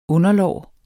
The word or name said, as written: underlår